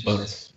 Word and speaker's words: Bowness: It's pronounced bonus. But yeah, it's spelled Bowness.